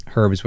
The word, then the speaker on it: herbs: The listeners and me don't have to worry about making fun of you for anything you say except herbs with a hard H.